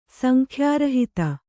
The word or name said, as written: ಸಂಖ್ಯಾರಹಿತ